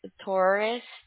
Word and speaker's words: Torres: Y-E-S-I. – Okay. And your last name, Jessie? – Torres, T-O-R-R-E-S.